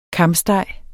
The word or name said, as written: kamsteg